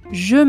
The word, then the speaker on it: Je: Je m'appelle. Je m'appelle. Je se pronuncia con un sonido j que se hace con la lengua y los dientes inferiores y soplando aire.